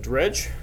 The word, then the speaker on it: Dredge: We're here with Gavin from Dredge. Is that how you say it? Or is it? – That's how you say it, yeah.